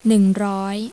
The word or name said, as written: neung roy